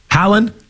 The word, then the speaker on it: Haaland: Haaland? – Yeah.